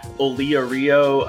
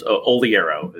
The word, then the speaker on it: Oliaro: I've heard Olia Rio. I've heard all kinds of stuff. So it's same stuff, different day. What I've started having to do is put my name phonetically next to my signature line, and that typically helps people out. But you're pretty close. Oliero is.